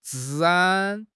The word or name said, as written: 治安